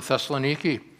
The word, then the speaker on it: Thessaloniki: From there, they made their way furthermore towards Thessalonica. Today, they pronounce it Thessaloniki.